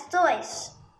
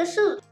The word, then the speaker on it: Seuss: One more interesting fact Dog Seuss is supposed to be pronounced as Zois, but not as Dr. Seuss. But everyone today says Dr. Seuss.